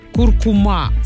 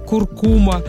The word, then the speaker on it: куркума: Теперь основным вариантом считается ударение на последний слог. Об этом в РИА Новости сообщил научный сотрудник Института русского языка имени Виноградова, председатель филологического совета «Тотального диктанта» Владимир Пахомов. По его словам, вариант «куркума» стал основным, а прежнее произношение «куркума» перешло в категорию допустимой старшей нормы.